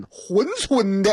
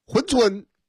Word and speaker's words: 珲春: 嗨，我的这个老母亲呢，她了人家是吉林珲春的。那位说读错了，那字儿念灰，我知道。但是在我老家，那嘎的，哈哈，东北人的发音，就是念珲。珲春，跟大伙说一声啊，这个哎来了，谁来了？